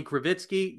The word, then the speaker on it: Krivitsky: So at Bobby Krivitsky, you can see how it's spelled. K-R-I-V as in Victor, I-T-S-K-Y is the last name for those listening to the audio version of this.